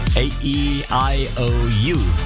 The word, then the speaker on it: AEIOU: Aussprechen kann ich sie nicht, wird geschrieben A-E-I-O-U, also wie die Vokale. A-E-I-O-U. Okay, ich kann sie doch aussprechen. A-E-I-O-U, Oliver Heldens, um kurz nach halb.